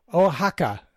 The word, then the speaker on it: Oaxaca: Oh, Oaxaca, Mexico. – Okay. – Bert, I think the X is pronounced as an H.